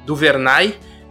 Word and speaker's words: DuVernay: Duvernay, que foi do Vernay, é isso? Essa é a pronúncia. – DuVernay, DuVernay, DuVernay, É de alguém, né? É DuVernay.